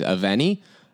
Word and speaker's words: Y Fenni: So I am from a teeny little town called Abergavenny, or in Welsh it is Aveni. It's as it's spelled. – in Welsh, Y-F-E-N-N-I. And then in English is A-B-E-R-G-A-V-E-N-N-Y.